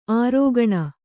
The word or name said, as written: ಅರಗಿಣಿ